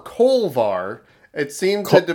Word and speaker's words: Kolbar: But uh, I, I watched with subtitles, and it said K O L B A R. Oh, Kolbar.